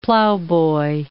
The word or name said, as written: plowboy